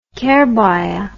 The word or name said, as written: kerbaya